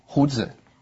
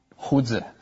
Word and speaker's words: 胡子: Oh, that is 胡子. – Okay, and how do you say beard in Chinese? – Oh, that is 胡子.